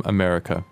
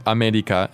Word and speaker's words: アメリカ: It's not America, but it is... – America. America. – So it's sort of somewhere in between a tapped R and an L.